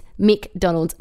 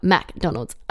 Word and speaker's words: McDonald's: It's McDonald's, not MacDonald's.